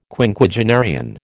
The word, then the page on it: Quinquagenarian: American English